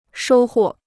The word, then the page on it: 收获: shōu huò